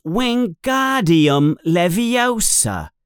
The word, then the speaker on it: Wingardium Leviosa: You're saying it wrong, says Hermione in an impatient voice. It's Wingardium Leviosa. You emphasize the gar.